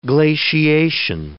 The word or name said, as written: glaciation